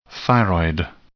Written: thyroid

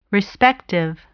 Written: respective

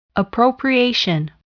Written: appropriation

